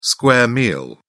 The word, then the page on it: square meal: British English